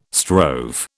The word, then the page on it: strove: British English